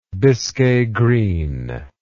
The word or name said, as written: biscay green